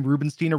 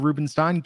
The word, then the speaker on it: Rubenstein: How do you pronounce my last name? Rubenstein or Rubenstein? Guess what? Never had a preference because people have been saying it both ways my entire life.